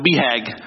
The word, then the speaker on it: BHAG: And one of the terms that he introduced us to in this book was, we pronounce it BHAG, but it's B-H-A-G, Big Hairy Audacious Goal.